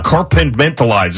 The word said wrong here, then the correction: compartmentalized: is another word I can't say. How do you say that? Compartmentalized.